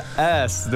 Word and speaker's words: S: 呃，参加一个师资的培训，就是教那个儿童美语，然后最后一关是要试教，然后我就会告诉他们，比如说发音，比如说 A B C D E F G 这样子，然后到最后的时候讲到那个 S 的时候，我说 OK，follow me，ass ass，然后后来我就看到那个台下的老师脸皱起来，然后我就说，哎，奇怪，我发音有错吗？后来老师说，Are you saying ass？应该是 S 对不对？